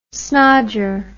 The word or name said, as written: snodger